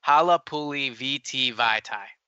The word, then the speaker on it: Halapoulivaati Vaitai: and I saw someone saying that we were mispronouncing Halapoulivaati Vaitai. And so I, I, looked it up and it is, I'm looking at the pronunciation right now Halapoulivaati Vaitai.